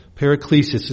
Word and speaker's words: paraklesis: Paraklesis is spelled P-A-R-A-K-L-E-S-I-S, paraklesis. It's used 29 times in the Authorized Version of the New Testament, as I mentioned.